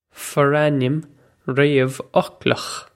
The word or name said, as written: forainm réamhfhoclach